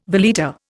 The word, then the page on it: valeta: Indian